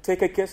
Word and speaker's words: Tychicus: I think the CH is silent, and it's pronounced as a K, rather. Tychicus.